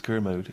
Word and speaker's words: Kermode: I think it's Kermode, yeah. – So you put the emphasis on the Ker so it doesn't sound like a Kermode.